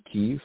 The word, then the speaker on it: Kyiv: I am in favor of the democracy in Kyiv. I think I was saying Kiev before, the way the Russians pronounce the name of the city. But Kyiv, I'm in favor of the fact they're democratic, that they want to have freedom.